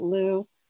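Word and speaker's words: Lleu: Her husband, I pronounce it Lou, is flaxened hair, and he's a hero in the stories.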